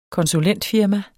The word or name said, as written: konsulentfirma